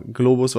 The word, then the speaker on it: Globus: Nein, da, da, heißt das nicht ein, ein Kloben? Da, wo du herkommst, spricht man das so aus, ne? äh, ja, ich, genau. Globus war übrigens gemeint, aber.